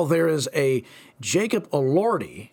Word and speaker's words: Elordi: And that's how you say it, Jacob Elordi.